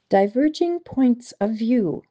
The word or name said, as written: Diverging points of view